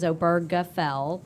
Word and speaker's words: Obergefell: And so then 2 years later, along comes Obergefell, and we have to make sure we stop and emphasize, a lot of people mispronounce the guy's name, but I've actually heard him pronounce it himself, and it is Obergefell with a hard G. So along comes Obergefell, and Obergefell actually was a constellation of cases.